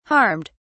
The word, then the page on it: harmed: British English